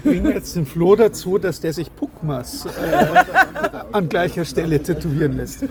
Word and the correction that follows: Packmas: Sag mal, kannst du nicht unseren Namen auch einfach mal richtig aussprechen? Es ist Packmas am Spätel. – Ja, Packmas.